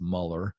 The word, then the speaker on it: Mueller: His name's George Mueller. It's technically pronounced Mueller.